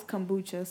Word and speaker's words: kombucha: Is it kombucha or kumbaka? I I thought it was kombucha.